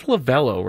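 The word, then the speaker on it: Lovullo: It's Lovello, right? It's spelled Lovolo, but it's Lovello.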